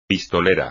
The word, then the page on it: pistolera: espagnol